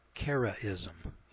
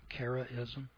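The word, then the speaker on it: Karaism: Let me spell that for you. It's K-A-R-A-I-S-M. Charism. Is it Charism?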